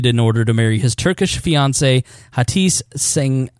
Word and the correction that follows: Cengiz: Cengiz. Yeah, there we go.